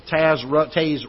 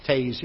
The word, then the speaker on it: Taze: A man by the name of Charles Taze Russell. I always pronounced it Taz, but somebody pronounced it Taze here recently, and I thought, well, that must be the proper pronunciation.